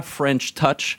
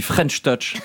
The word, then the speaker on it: French Touch: D'ailleurs, French Touch, c'est de l'anglais, mais ça ne se prononce pas French Touch. On dit French Touch.